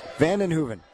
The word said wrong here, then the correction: Van Evenhoven: It's Van Evenhoven is actually how you say it.